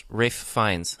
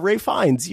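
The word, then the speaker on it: Ralph Fiennes: Pronounced Rafe Fiennes. – Yeah, Rafe Fiennes, you know.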